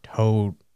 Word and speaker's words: Todo: Toto? – No. Todo. It's spelled T-O-U-D-O-U. – Oh, okay. Todo. – Yeah. And it's A-O-I.